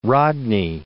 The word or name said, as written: Rodney